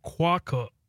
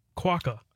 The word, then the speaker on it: quokka: Okay, so it's quokka. Quokka.